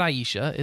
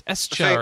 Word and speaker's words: Escha: That's right, Atelier Eisha and Eisha Escha. How do you even say that? Escha. – Aisha? – No, it's not Aisha. It's Escha or Escha.